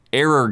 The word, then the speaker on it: 404: So this is a tricky one to say on a podcast because it's written as 404 game reset, but it's pronounced as error game reset.